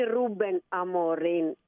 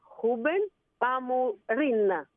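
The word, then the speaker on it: Rúben Amorim: Εγώ έχω βρει ότι λέγεται όχι Ρούμπεν Αμορίν, όπως γράφεται, αλλά Χούμπεν Αμορίν.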